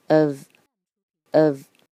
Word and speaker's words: of: OK，我们来现在慢速读一遍这每个单词。Let, let, go, go, of. of 这个 o f 千万不要再读成 off off 了，它是 of of me me 这个嗯的音要到位。What's what's the the matter matter with with you you 接下来呢，我们超级慢速读一遍。Let go of me.